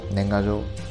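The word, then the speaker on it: nengajō: Y Nengayo, Nengayo se dice así, pero bueno, es un postal.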